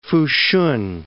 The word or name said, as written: Fushun